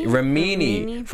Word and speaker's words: Remini: Leah Remini? – Remini.